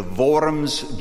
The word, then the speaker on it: Worms: Can't give the background for lack of time, but he's on his way to Worms, Germany. W in German is pronounced like a V. It is Worms.